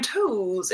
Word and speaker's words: Toews: Okay, so Matt also says, new book recommendation, Women Talking by Miriam Toes. – It's 3-0, wow. Yeah. No, I can't. – It's actually pronounced T-O-E-S. – Okay, Okay, so by Miriam Toews,